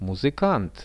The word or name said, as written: muzykant